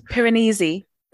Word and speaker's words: Piranesi: Piranesi. I don't know how to say it. – Piranesi. – Is that it? – Yeah, Piranesi. – Piranesi. Like Pyrenees. Yes. That sounds like Pyrenees.